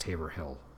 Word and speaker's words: Haverhill: It looks like it's pronounced Haverhill, but we're so used to pronouncing it as Haverhill that when I pointed that out to somebody in my group, they couldn't even imagine how somebody would pronounce it another way.